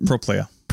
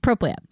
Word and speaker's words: Propylaea: The closest you got to that is the Propylaea, although I think I've been saying that wrong, haven't I? – I think so. – What is it? – Propylaea. – Propylaea, yeah. – That's how I heard it pronounced on our little tour guide.